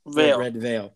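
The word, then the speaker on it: Redveil: It's a Red Veal. Am I saying that right? – Red. – Or am I saying that right? Red Veal. Red Veil. – Like a veil.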